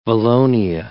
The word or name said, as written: valonia